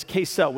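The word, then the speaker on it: Kesel (כסל): You see, the, the Hebrew language has this word, and it's called kesel. It's K E C E L. It's pronounced Kesel, and it's translated lots of different ways throughout the Old Testament.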